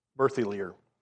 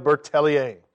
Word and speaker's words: Berthelier: And okay, when I first read all this stuff, I was listening to it with my computer reading it, and that's Philibert Berthelier. That's what I said for a long time. But Pascal Deneau has gone through all these names with me and tried to help me pronounce them right. They're still going to be very anglicized, but I'm not going to butcher them as much as I used to. So Philibert Bertellier.